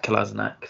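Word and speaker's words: Kolašinac: That's Sayad Kolasinac for the next time you guys want to pronounce it.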